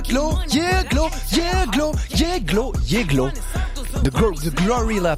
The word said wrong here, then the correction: GloRilla: pardon, j'ai failli écorcher son blaze et dire Gorillaz. – Glorilla. – Glorilla. – Glorilla. – Ce L avec la langue pâteuse, Glorilla.